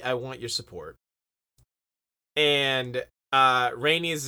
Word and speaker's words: Rhaenys: say her name wrong. – No, that was right. – Well, that time I did. I called her like Rhaenyrus or something earlier.